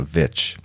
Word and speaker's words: Stojakovic: Are you a Vic or a Vich? – Well, phonetically it's Vic, but pronunciated properly, if I can do that, is Vich. – Vich, right. Correct.